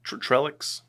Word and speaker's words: Trellix: Trellix? – Trellix.